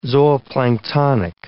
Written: zooplanktonic

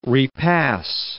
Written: repass

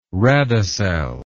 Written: radicel

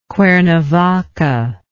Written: cuernavaca

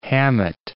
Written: Hammett

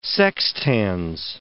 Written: Sextans

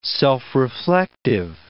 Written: self-reflective

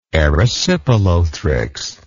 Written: erysipelothrix

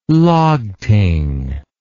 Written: Lagting

